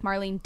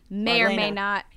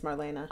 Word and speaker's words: Marlene: Have I been saying it wrong all these years? – I mean, I think that's the American way of saying it. – Oh. – But in German, it's Marlena.